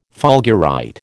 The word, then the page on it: fulgurite: American English